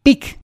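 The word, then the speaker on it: Pygg: que llamaban PIC, que se escribía o que se escribe P YGG, un nombre muy parecido a la palabra pig, que significa cerdo en inglés.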